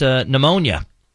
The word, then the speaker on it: pneumonia: How about pneumonia? – Woo! All right. That starts with a P. – P. – P is silent.